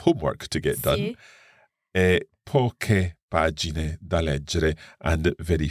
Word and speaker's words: poche: and therefore we need to add in an H in order to keep that C hard. Likewise with poche, the feminine plural form.